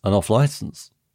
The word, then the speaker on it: off-licence: It's the stress on the off rather than on-licence most people go with. – An off-licence. – Yeah, there you go. Are you hearing the difference? – An off-licence.